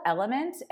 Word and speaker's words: LMNT: Yeah, so it's called Element, and it's spelled L-M-N-T.